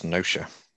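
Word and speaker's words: Gnosia: According to the internet, the G is silent. – Oh, so no show. – So it's Gnosha.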